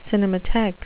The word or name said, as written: cinematheque